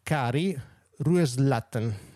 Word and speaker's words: Kari Rueslåtten: E proverò a pronunciare il nome. Eh. Cari Rueslatten, apparentemente si pronuncia più o meno così, mm.